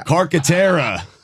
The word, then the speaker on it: Carcaterra: One of the commentators' name was Cacantera. – I don't, that can't be the way you pronounce it. – I think it's Paul Cacantera. It sounded like Cagalera. – Carcaterra.